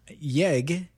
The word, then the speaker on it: YEG: YEG is airport code for Edmonton. – Y-E-G. – Alberta. Yes. Yeah, I've been pronouncing it as if it was its own word.